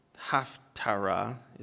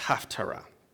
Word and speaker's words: Haftarah: The word Haftarah, is how it's pronounced, actually means conclusion. So it's not half Torah as in half the Torah. That's what I thought for many years. It's Haftarah. The reasons that specific selections were chosen has been lost to history.